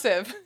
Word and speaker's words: sieve: Oh, it's pronounced sieve. – She's like, well, I knew that, but I'm saying when it's combined with pensive, it's a combination of pensive and sieve is what I'm saying.